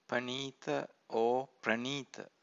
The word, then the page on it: paneeta: Pali